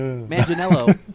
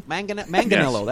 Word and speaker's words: Manganello: My next pick, Joe Mentally Negro. I can't even say his name. – Joe Mangello, or whatever his name is. Yeah. – Manganello. Manganello, yes. That's it. Joe Manganello.